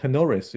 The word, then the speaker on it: Tenoris: They are from a company called Tenoris, if I pronounce that correctly. – You got it.